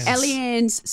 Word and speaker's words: Allianz: How do we say it? Allianz? – Allianz Stadium is in Sydney.